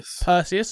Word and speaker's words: Perseus: The trailer itself is heavy on the Cold War and introduced suppliers to the Soviet spy, codename Persians. According to the teaser and some light googling, Persians, I think I'm saying that right, Persians, I think so, go on. – Perseus, sorry, not Persians.